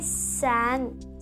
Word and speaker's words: san: So 3 is san. – So say it with me, sang. One syllable, sang.